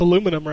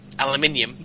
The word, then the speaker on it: aluminum: You'd be able to pronounce aluminum right. – Aluminium. I do. – Fuck, there's no I at the end of it.